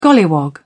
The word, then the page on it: golliwog: British English